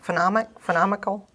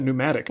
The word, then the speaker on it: pneumatic: How do you pronounce that? Phenomical? – Pronounce that pneumatic.